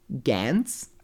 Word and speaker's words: Gantz: if I'm saying it correctly, Gantz. – Oh, pretty good. Nice job.